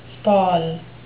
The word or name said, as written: spall